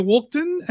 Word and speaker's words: Walkden: But my name is Peter Walkden, and the surname is spelt W-A-L-K, D for dog, E for echo, N for November.